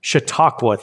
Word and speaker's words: Chautauqua: By the way, Nicole, who's from, what's the institute that Michael Chautauqua. Eisner went to? – Chautauqua.